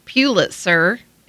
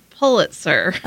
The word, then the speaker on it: Pulitzer: The board chose not to issue a Pulitzer Prize for fiction. – Okay, so I did find it in the book. Robert says, it's not Pulitzer, it's Pulitzer.